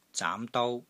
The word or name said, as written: ZAAM2 DOU1